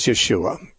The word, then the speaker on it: Yeshua: Well, as I understand it, the Hebrew pronunciation of his name is Yeshua.